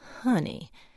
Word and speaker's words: Honey: Though, to get it right, you had to let the first syllable hang a bit, Honey.